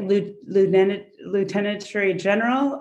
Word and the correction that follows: Lunetterie Générale: I don't know. Oh, Lunetterie Générale, yes.